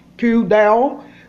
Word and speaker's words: P'kudei: Hello, for Torah portion number 23, which is called Q-Del, which is spelled P, apostrophe K, U, D, E, I.